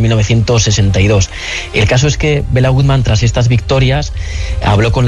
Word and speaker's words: Béla Guttmann: Hablamos en la introducción la de Bela Goodman. ¿Quién era, no sé si se dice así, quién era este personaje? – Pues Béla Guttmann fue un entrenador que nació en el año 1899 en Budapest.